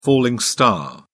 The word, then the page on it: falling star: British English